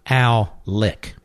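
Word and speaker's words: Alec: I don't think you pronounce it. The old school pronouncing is Al Lick. – Right. – I think his name is Al Lick.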